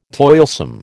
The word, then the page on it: toilsome: American English